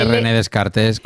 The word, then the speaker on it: René Descartes: No, René Descartes. ¿Puedo llamarle René Descartes como toda la vida, por favor?